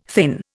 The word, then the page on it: thin: Indian